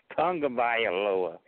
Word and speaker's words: Tagovailoa: Tonga. It's Tagovailoa. – Okay. What? – That's how you pronounce a Samoan name.